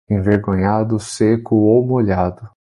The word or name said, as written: molhado